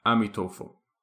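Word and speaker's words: Amituofo: I will be using the Chinese pronunciation of his name, which is Amitofo.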